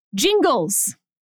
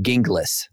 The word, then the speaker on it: Gingles: And this is one of the lines, known to his friends and colleagues as Skip Jingles, parenthetical, pronounced Jingles with a J. Born and raised in Gaston County, he was the named plaintiff in that case who goes on to become a judge, etc. So done. We're done with this. It's Jingles. – So say goodbye to my preferred pronunciation of Gingles.